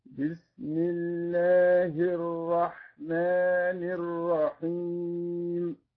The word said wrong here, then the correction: الرحيم: بالنسبة للرحيم، كلمة الرحيم لم تنطق الحاء فيها كما نطقت في الأولى، فلقد قلت الأولى بالرحمن، وهذا نطق جيد لحرف الحاء، فعليك أن تكرر حاء الرحيم بمثل ما كررت حاء، بمثل ما نطقت حاء الرحمن، فقل على بركة الله الرحمن الرحيم.